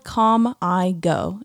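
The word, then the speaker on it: CalmiGo: It's spelled Calm I Go. And I think they say Calm-I-Go is how they say it.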